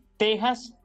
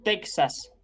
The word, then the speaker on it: Texas: Así que diremos tejano, Texas y no Texas no texano. Esto que quede claro, porque en ocasiones se escucha una pronunciación inadecuada, específicamente cuando aluden a Texas y dicen incorrectamente Texas.